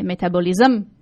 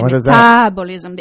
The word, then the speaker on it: metabolism: I used to say, metabolism. Now I know how to say metabolism. – That? – The accent is on the A.